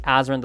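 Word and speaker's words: Azren: That is spelled P-O-L-Y-G-L-O-T-A-Z or Z, depending on your country, R-E-N. You can also simply search Azrin the Language Nerd on whatever your favorite social network is. Again, that is spelled A-Z or Z, R-E-N, the Language Nerd.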